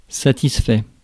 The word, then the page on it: gratified: American English; /ˈgra.ti.faɪd/